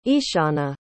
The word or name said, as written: eashana